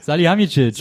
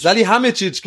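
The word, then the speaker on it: Salihamidžić: Also, sag mal, wie der heißt. – Salihamidzic. – Salihamidzic, genau. So, deswegen kam ich gerade durch. Ich habe es falsch gesagt.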